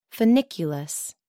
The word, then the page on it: funiculus: American English